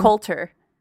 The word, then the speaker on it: Coulter: Coulter.